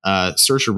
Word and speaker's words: Saoirse: Saoirse Ronan plays Christine Lady Bird McPherson. I said it right the first time.